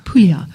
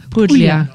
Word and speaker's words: Puglia: So I highly recommend Puglia, which is spelled Puglia, which is kind of like Puglia. – Puglia.